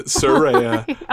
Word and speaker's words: Saraya: I also do a lot of the AEW post shows, and we talked a bunch about like Jade, Saraya. I want to pronounce it correctly. – Yes, please do. She will come from. – Yeah, Saraya.